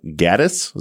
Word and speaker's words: Gaddis: Gaddis.